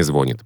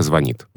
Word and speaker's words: звонит: То есть сейчас, например, ни один нормативный словарь русского языка не разрешает ударение «звонит». Правильно, только «звонит». – Угу. – И грамотный человек будет говорить только «звонит».